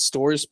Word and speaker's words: Storrs: Is it Storrs, Pete? Am I saying that right? Do you know where they're saying it? You're saying it right.